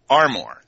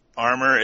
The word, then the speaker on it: Armour: My family, and this is comes from my father's parents, insisted that it was Armour, so you accent both syllables equally.